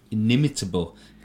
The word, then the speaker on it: inimitable: Inimitable. God, actually, I pronounced that right. In fact, I remember you saying that once before on the podcast and me going, inimitable.